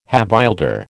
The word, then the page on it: havildar: American English